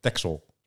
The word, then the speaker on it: Texel: Ik spreek het ook uit als Bruxelles, net als Texel. – Texel. Ja, daar ben ik het mee eens. – Ik heb nog nooit een X als een X uitgesproken. Jij? Of was een S bedoel ik, Texel.